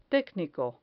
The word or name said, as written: tecnico